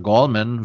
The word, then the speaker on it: Gallman: war der Running Back Wayne Gallman oder Gallman wird er ausgesprochen.